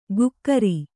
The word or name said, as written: ಗುಕ್ಕರಿ